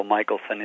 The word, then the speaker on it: Michelson: Hello, my name is Ben Michelson, and I was born and raised down in Bolivia, South America, and a lot of people will pronounce my name Michelson, and it really doesn't matter in English, although Michelson is the right pronunciation.